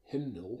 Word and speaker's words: Hymnal: White Winter Hymnal. – Mm-hmm. – Is that how you pronounce that yes.